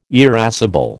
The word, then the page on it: irascible: American English